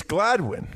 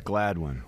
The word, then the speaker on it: Gladwyne: How do you pronounce the town that's spelled Gladwyne? – Gladwyne? – Yeah, but when the caller, you said Gladwyne. – And what did I say the second time? – It's Gladwyne. – Gladwyne. – You live near there, right? It's Gladwyne, right? – Yeah, it's Gladwyne.